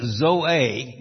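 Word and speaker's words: Zoe (ζωή): The word Zoe, now that's the way you pronounce it in Greek.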